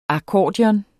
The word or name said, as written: akkordeon